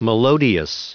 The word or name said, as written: melodious